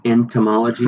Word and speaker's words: entomology: Entomology.